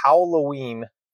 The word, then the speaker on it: Howloween: And it was called the Howloween 5K. And it's spelled H-O-W-L-O-W-E-E-N. So Howloween, like how?